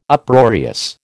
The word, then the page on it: Uproarious: American English